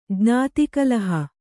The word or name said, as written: ಜ್ಞಾತಿಕಲಹ